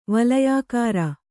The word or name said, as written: ವಳಯಾಕಾರ